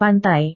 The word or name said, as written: pantai